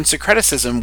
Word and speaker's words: syncretism: Second of all, when you start talking about whether or not it is acceptable for you to do some sort of modern syncretism with your deity, syncretism, did I say it right? – Syncretism.